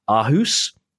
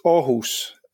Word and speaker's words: Aarhus: How was my pronunciation of Aarhus? – It's Aarhus, if it's to be. – I was miles off then.